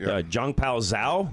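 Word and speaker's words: Changpeng Zhao: I'm sure I'm going to say this name incorrectly, Zhongpao Zhao, am I saying that right? – That's right.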